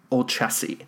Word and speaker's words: Olcese: And we are also joined by guest Abby Olcese.